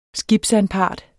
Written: skibsanpart

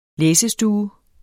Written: læsestue

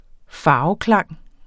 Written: farveklang

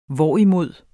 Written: hvorimod

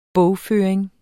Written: bogføring

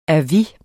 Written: advis

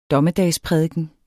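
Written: DOMMEDAGSPRÆDIKENERNE

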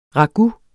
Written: ragout

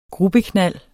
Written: gruppeknald

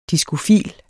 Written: diskofil

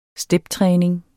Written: steptræning

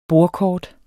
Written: bordkort

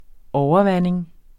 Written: overvanding

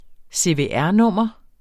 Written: CVR-nummer

